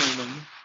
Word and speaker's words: Leung: How do you pronounce his last name? I don't want to mispronounce uh, it. Tony Leung.